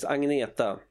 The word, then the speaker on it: Agnetha: Well, the correct Swedish pronunciation is Agneta.